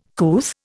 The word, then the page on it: couth: Indian